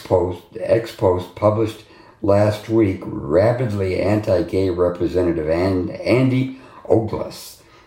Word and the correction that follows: Ogles: I think he pronounces his name Ogles. It's O-G-L-E-S.